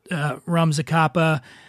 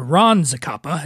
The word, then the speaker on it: Ron Zacapa: And again, everybody, check out Rum Zacapa, or Ron Zacapa is how you say it.